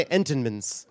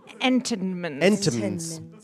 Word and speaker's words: Entenmann's: Why is the N inside of Entenmann's not pronounced? Now that I think about it, you don't say Entenmann's. – Entenmann's.